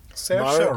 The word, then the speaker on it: Saoirse: Saoirse? Why can I not say her name now? – Saoirse Ronan.